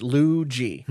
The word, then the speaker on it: Ludjy: Luigi, great name, L-U-D-J-Y, pronounced Luigi.